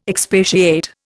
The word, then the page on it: expatiate: Indian